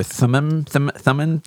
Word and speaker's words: Thummim: Thummim.